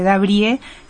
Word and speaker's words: Dabrieux: Fundación Di Mauro Dabrié, y allí estaremos en contacto. – Vamos a aclarar a la gente que Dabrié se escribe Dabrieux, termina en X, para que lo puedan buscar bien.